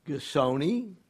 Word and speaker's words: Gisonni: I'm here with Diana Gisonni. Is that how you pronounce Gisonni? Gisonni, yes, very close, very close. – Gisonni. – Gisonni. Like a G. – Oh, like G. A G, okay. Gisonni.